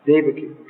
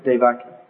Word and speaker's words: Devaki: Devaki. Okay, not Devaki. As we often hear in America, Devaki.